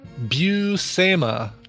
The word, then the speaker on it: Buscema: Here's a pronunciation. Buscema. Sal Buscema and John Buscema.